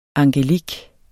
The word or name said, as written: angelik